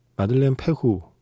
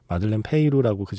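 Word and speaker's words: Madeleine Peyroux: 이 곡을 오늘은 마들렌 페후 네. 이 정도로 발음을 하는 것 같습니다. 마들렌 페이루라고 그전에는 많이 이야기를 했었는데요.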